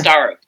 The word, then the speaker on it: Starro: I keep saying it wrong. It's Starro. Starro.